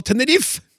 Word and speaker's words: Tenerife: På spansk uttaler man eh, tydelig en på slutten, Tenerife, og denne en er også med i den norske skrivemåten. Hvorfor i all videste verden sier vi da Tenerife?